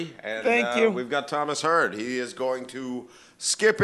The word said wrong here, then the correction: Skype: in. – Skype in. – Skype. – The Y is sometimes pronounced like an I.